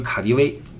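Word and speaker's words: KaDeWe: 嗯，大家常去的买东西的地方啊，你比如说德国，德国呃，就说柏林吧，柏林有一个卡迪威，大家可能听说过啊，德语是 KDW 啊，德语发音是卡迪威，卡迪威百货，欧陆面积最大的一个百货商场，哇，那巨大无比，跟一堡垒似的。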